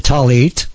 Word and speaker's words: tallit: Okay, and wearing the tallit, pronounced T-A-L-L-E-E-T, but it's written T-A-L-L-I-T, and you could look that up, the Hebrew word tallit, and the tzitzi is the fringe, tzitzit.